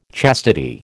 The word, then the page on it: chastity: American English